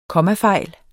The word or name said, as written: kommafejl